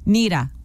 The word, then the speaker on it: Nira: Neera. – Neera. – Neera. – It's spelled N-I-R-A.